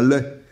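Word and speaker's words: legs: mais en fait, à la faveur d'une lecture, je ne sais plus laquelle, j'ai découvert que la vraie prononciation, c'est un lait euh, LAI, oui. Et euh, on tolère l'aigle, mais l'élégance doit nous pousser à l'aigle, parce qu'on a rajouté ce G par la suite, euh, en fonction du verbe léguer, mais en vérité, ça vient de laisser.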